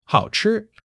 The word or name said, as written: hao3 chi1